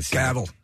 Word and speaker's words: Cavill: Cavill.